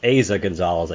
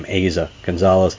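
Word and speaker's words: Eiza González: Aza Gonzalez, I think is how she actually pronounces her name. Aza Gonzalez.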